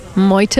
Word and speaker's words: Meute: And your name, Meute, am I pronouncing it correctly? What does it mean? – You pronounce it perfect, by the way.